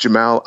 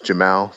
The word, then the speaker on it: Jamal: It's like the first name is pronounced Jamal. Like Jamal. – Phonetically, it's like Jim and Al.